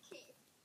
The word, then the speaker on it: Keefe: I was like, Kefe. – Yeah. Keefe? I don't know. – Who names their kid Keefe? – Yeah, Keefe. Okay.